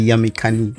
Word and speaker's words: Yamikani: Yamika Golden Maiwanga. Am I saying right? It's actually Yamikani.